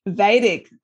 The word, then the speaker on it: Vedic: He's also the CEO of The Boardroom, a peer support community for business executives and owners, and a Vedic, is that how you pronounce it? – Vedic.